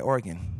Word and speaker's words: Oregon: They say Oregon. – Who says Oregon? – People that aren't from here. – I'm not from here. I think I say it right. Oregon. – Yeah, that's it.